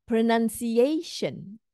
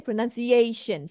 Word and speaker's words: pronunciation: Pronunciation. A is the stress. Okay, pronunciation. So it's not pronunciation. Too many stress there if you do that.